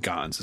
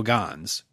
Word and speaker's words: Ganz: I think Ganz is how you pronounce it. – I think it's probably right.